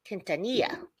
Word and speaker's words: Quintanilla: I can't roll my R's. Quintanilla. Perez.